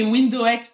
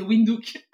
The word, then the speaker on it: Windhoek: J'ai prononcé Windhoek pendant très longtemps. Un jour, il y a une agence qui m'a dit Madame, c'est Windhoek.